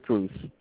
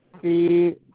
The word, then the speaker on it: Cruz: Lewis Cruz. Cruz.